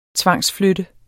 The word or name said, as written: tvangsflytte